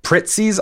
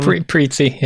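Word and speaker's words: Prizzi: And your choices are going to be Prestige films from 1985, Kiss of the Spider-Woman, Pritzi's Honor. – Pritzi, yeah, sure.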